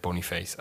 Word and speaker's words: Boniface: Also, der Verein sagt Boniface.